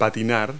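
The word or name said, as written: skate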